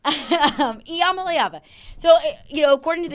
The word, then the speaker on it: Iamaleava: Is it Ia or Ya? – Iamaleava. Yes. – Iamaleava. Okay.